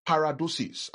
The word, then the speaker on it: paradosis: Now that word there, ordinances, is the word in the Greek, paradosis. I'll spell that for you. Paradosis, para, P-A-R-E, para, P-A-R-E. Then dosis is D-O-S-I-S, paradosis, right?